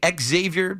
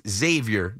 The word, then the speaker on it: Xavier: People say Xavier, but I think it's Xavier McKinney. It's Xavier. My friend Xavier Scruggs pronounces his name Xavier. It's not X, it's Xavier.